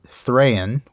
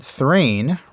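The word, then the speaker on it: Thráin: and that Thrain, rhyming with train, is the most popular pronunciation. Anyway, that is the pronunciation I'll stick with, and you're welcome to choose the one you like.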